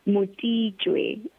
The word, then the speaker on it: Motitshwe: just butcher your surname? It's Motitshwe. – Mutijwe.